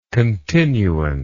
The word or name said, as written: continuant